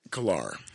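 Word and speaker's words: Calar: So now Wellington, the mayor is, it's, I think it's pronounced Kalar, Chelsea. It's C-A-L-A-R. – I would bet Kalar. – Kalar. Yeah. Kalar, so overwhelmingly won. And then trustees, Brian Mason, Sherelle Teetz, T-I-E-T-Z, and David Wiegand, W-I-E-G-A-N-D.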